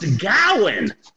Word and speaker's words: Gawain: The first thing that shocked me is that uh, Sir Gawain, apparently it's pronounced Gowan.